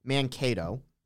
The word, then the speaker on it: Mankato: First off, it's actually pronounced Mankato. I just looked Mankato. It up.